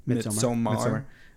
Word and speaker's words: Midsommar: Again, Midsommar. How do you say it? – Midsommar.